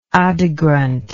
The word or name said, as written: Adi Granth